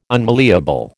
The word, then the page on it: unmalleable: American English